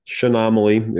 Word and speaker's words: Chaenomeles: Shinomale, C-H-A-E-N-O-M-E-L-E-S. Put that in front of someone and ask them to pronounce it. Shinomale is the way it's generally pronounced.